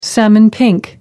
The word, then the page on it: salmon pink: American English